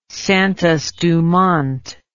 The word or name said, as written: Santos Dumont